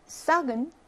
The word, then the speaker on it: sac: Sac은 sac 발음하죠. 발음합니다.